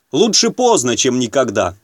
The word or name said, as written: лучше поздно чем никогда